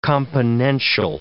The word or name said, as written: componential